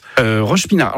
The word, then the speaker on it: Roche-Pinard: Vous à Roche-Pinard. Alors, c'est ça, hein, c'est bien comme ça qu'on prononce. – Roche-Pinard.